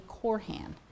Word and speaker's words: Koorhan: When I got married, my last name became Corhan. K-O-O-R-H-A-N.